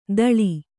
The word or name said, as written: ಥಾಳಿ